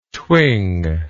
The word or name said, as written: thwing